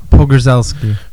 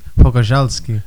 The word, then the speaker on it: Pogorzelski: Pogorzelski. – Phil Pogorzelski. No? – Unless you want to do it the Polish way, which would be Pogorzelski.